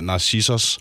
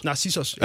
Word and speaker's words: Narcissus: Men den her sang, den i hvert fald også musikvideoen, den er inspireret af Narcissus, er det sådan, det udtales? – Narcissus. – Narcissus, ja. Ja, som jo trods alt handler om en, der er optaget af sig selv.